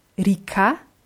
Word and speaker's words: река: Rika heißt auf Russisch Fluss. Rika. – Ja, wird mit je geschrieben zwischen R und K, wird aber eher wie I ausgesprochen, weil wir das A betonen.